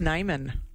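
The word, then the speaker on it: Niman: I believe it's actually pronounced Nyman. I always used to call it Neiman, but really? – It's actually Nyman.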